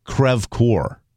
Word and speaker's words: Crevecoeur: Well, another one that she pronounces differently that is actually the actual pronunciation is Crevecoeur. – Crevecoeur. – Not Crevecoeur. Yeah. – Well, that would be, she's right. Crevecoeur would be correct.